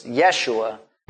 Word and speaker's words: Yeshua: it's spelled in your Bible Jeshua, but it's Yeshua, and has that spelling of the Yod, the Shin, the Vav, and the Ayin.